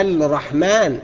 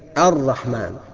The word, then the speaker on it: الرحمن: طيب الراء الرحمن، ما بقولش الرحمن، انما صحيح بالادغام وهي الرحمن.